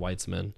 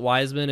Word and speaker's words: Weitzman: So M for Michael, A for Anthony, and then just my last name, Weitzman. Okay. You can pronounce it Wiseman if you want.